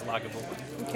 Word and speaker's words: Lagavulin: I've also heard it pronounced Lagavulin, but I believe the correct pronunciation is Lagavulin.